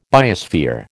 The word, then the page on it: biosphere: American English